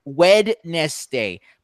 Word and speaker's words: Wednesday: It's spelled like Wednesday, but you don't pronounce it, but you pronounce it Wednesday.